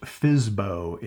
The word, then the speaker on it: FSBO: For sale by owner, FSBO, pronounced FISBO in the business.